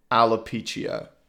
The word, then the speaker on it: alopecia: Okay, uh, I think it's pronounced alopecia. We'll just go with that.